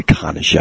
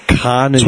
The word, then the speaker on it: Carnegie: The Carnegie. I heard someone pronounce it like that once. – Are you serious? – All real estate agents pronounce it that way. – Dude. – Carnegie. – Swear to God. Because it classes it up.